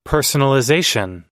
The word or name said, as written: personalization